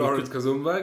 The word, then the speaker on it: Florence Kasumba: Florence Kasumba, genau.